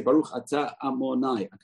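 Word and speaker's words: Adonai: So it's written Yud Kei Vav Kei, but it's pronounced Amonai.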